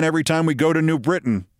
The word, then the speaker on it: New Britain: Let me say that correctly in the local vernacular. New Britain.